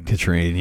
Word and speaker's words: Catherine: Later at a bar, Red is having a drink as Duval and his lady, Katrine. I'm going to try really, really hard to remember Katrine. – Katrine, yeah. – Because it's spelled in every single text and in the credits as Catherine.